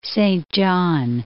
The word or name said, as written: Saint John